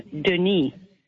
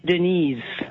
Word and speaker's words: Denis: It's spelled correctly, but it's a French name, Denis. – Yeah, Denis. – As in a female, it's Denise.